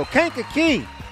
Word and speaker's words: Kankakee: Kankakee! I didn't know how to say it, but I do now.